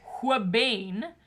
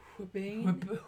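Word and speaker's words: ouabain: Huabane.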